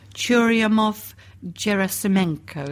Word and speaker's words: Churyumov-Gerasimenko: Not for nothing is it shortened to CG, because it's Churyumov-Gerasimenko, is that right? – Yes.